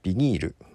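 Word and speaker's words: vinyl: そう、今日の朝、まあツイッターの方でですね、まあ藤井風がね、キングヌーのビニール、まあ正式な読み方でいくとバイナルかな、のカバーをしてたと、ライジングサンロックフェスティバル 2022 でね、というのがなトレンドにあったのかな。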